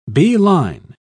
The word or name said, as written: beeline